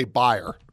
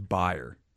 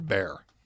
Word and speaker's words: Bayer: Pronounce it correctly. – I say buyer. – Correct. That's correct. It is buyer. – Everyone in America says bear.